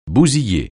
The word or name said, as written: bousiller